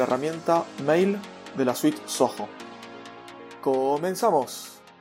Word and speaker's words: Zoho: Soho se escribe Z-O-H-O.